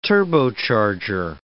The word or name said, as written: turbocharger